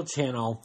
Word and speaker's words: Channel: But it's Daniel Channel, and I even hesitate in saying it, but I said it right there, but it's Daniel Channel, like channel, like the TV channel.